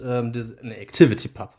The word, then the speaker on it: ActivityPub: Ah, und jetzt haben wir ActivePub und ActivityPub. – Ja. – Mensch. Jetzt, als Nerds hat natürlich direkt falsch ausgesprochen.